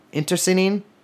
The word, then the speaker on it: internecine: I mean, there was a ton, and they engaged in both like interceding. I do not think I pronounced that correctly. The kind of warfare where you kill your friends too. Internecine.